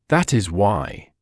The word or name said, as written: that is why